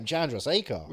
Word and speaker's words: Chandrasekhar: Chandrasekhar. – Jay Chandrasekhar. – Yeah. We get a cameo. – That's a long name, Chandrasekhar. – We get... – Chandrasekhar. What? Four syllables.